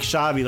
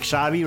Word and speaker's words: Xavi: So when you pronounce this X in Spanish, it's like Xavi, like Xavi, right? Xavi. this is Los Xavi Chatinos with Trejo de Mano.